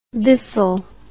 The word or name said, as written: bissel